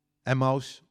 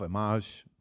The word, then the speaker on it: Emmaus: En er zijn twee gasten op weg naar een plek die heet Emmaus of Emmaus, net hoe je het wil uitspreken.